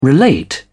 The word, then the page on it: relate: British English